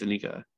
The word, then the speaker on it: Anika: Say it properly. – Played Anika. No, it's. I believe it's Anika. – Wait, is it double N or one N? – It's one N. It's the same way you spell it. – Yeah, Anika Noni Rose. – I was pretty sure. Okay, I'll say Anika Noni. It's always like a little bit triggering when people are like, "Anika." I always feel bad for correcting them.